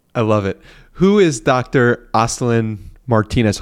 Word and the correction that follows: Olstyn Martinez: Hopefully I'm pronouncing that correctly. Dr. Aslan Martinez. And how does that play a role in what exactly you're talking about? – Yeah. So this is Dr. Olston Martinez.